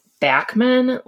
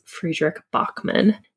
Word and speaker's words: Fredrik Backman: I think I pronounced it Bachman last week, but it is Friedrich Bachman.